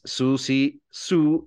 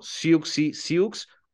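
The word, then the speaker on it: Siouxsie Sioux: Sí, la pronunciación correcta es Susie Sue y Susie and the Banshees. Si ustedes ven referentes ahí en YouTube, eh, de la televisión, por supuesto, del Reino Unido, así verán que se pronuncia, pero nosotros estamos más acostumbrados a ubicarla como Siuxi Siux o Siuxi and the Banshees.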